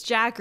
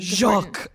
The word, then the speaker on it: Jacques: So I'm like, should I just pronounce it as Jack or is it Jacques? – Oh. – Like, no, be as pretentious as you possibly can. – Jacques. Okay, Jacques.